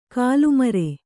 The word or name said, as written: ಗಲ್ಲುಮರ